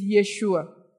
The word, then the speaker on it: Yeshua: Now, the question on Yeshua, actually the Hebrew is Yeshua. Not Jesus. Jesus is the Greek rendering, the Greek pronunciation, the Greek rendering of a name.